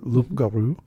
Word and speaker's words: loup-garou: Let's talk about the most famous image that we're used to seeing, the blue dog or loup-garou, which I guess it came, am I saying that right? Is it loup-garou? – Loup-garou, yeah.